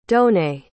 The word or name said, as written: donnée